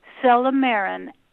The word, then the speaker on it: silymarin: Celermarin, like I talked about before, is a flavonoid in artichokes, and it's a celermarin. I'm going to spell it S-I-L-Y-M-A-R-I-N.